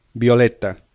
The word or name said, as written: violeta